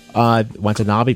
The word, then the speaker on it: Watanabe: Is that how you pronounce his name? – Watanabe, close enough.